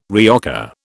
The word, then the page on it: reoccur: British English